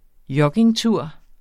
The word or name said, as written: joggingtur